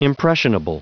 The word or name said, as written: impressionable